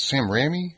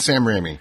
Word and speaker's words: Sam Raimi: And I always butcher his name. It's Sam Raimi? – Yep. – Raimi? So I think it's Sam Raimi. The correct pronunciation.